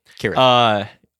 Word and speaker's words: Cirith: Yeah, Tolkien hated soft seas. – Okay, yeah. Okay, Cirith Ungol. – Cirith. – Oh, he hated soft seas. Okay, yeah, Cirith, got it.